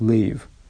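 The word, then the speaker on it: Лейв (לב): Ламед Бейс читается как Лейв.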